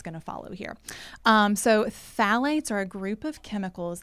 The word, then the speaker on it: phthalates: And initially, I thought I was going to mispronounce this word, but I got a little coaching before we hit record, and it is phthalates. – Yeah. – It's not how it's spelled, but phthalates is what we're talking about today.